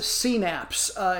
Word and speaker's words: CNAPS: I'm going to pronounce it CNAPS. It's capital C-N, lowercase a for stylization, capital P, capital S.